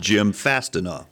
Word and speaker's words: Jim Fastenau: Jim Fastenau.